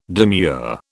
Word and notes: demure: British English

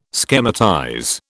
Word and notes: schematize: British English

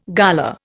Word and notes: gala: Indian

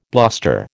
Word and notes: bluster: American English

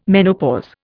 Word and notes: menopause: Indian

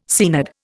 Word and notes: Seanad: Indian

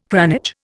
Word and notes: cranage: Indian

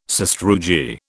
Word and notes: sastrugi: British English

